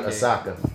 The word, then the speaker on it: Osaka: Either it's Asake or Asuka. I want to say Naomi Asaka.